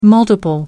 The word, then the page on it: multiple: American English